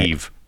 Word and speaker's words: Niamh: N-I-A-M-H. – N-I-A-M-H. Name her. – Mm-hmm. – Okay, well, how do you say Neve.